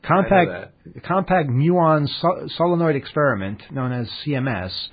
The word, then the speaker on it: muon: And the website, the compact, how do you say this word? M-U-O-N? – Muon.